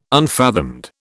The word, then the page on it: unfathomed: British English